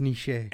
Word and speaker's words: Nietzsche: Is that it's Nietzsche.